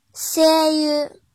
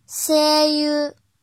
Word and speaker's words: 声優: 音读的时候读作 “c c c”，比如配音演员。声優、声優、声優。